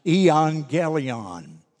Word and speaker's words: euangelion: The word is pronounced euangelion.